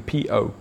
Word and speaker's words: po: The po, if you want to pronounce it exactly as you would in English, the P-O, po.